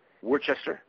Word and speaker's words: Worcester: He was a lawyer working with a very Zionist law firm in Worcester, Massachusetts. I think that's how you say it. Worcester.